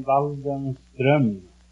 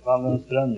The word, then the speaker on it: Waldenström: No, it's a umlaut on the O. In Sweden, you pronounce the name Waldenström. – Waldenström? – Waldenström, yes.